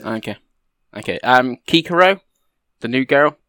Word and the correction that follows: Kiriko: Oh, you have mispronounced her name. – Oh, I can spell it for you. Kiriko. – Okay, you just mispronounced it. You didn't know it. Yes, Kiriko.